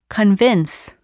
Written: convince

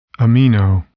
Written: amino

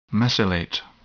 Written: mesylate

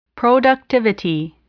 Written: productivity

